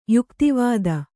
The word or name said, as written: ಯುಕುತಿವಿದ